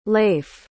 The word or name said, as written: Lafe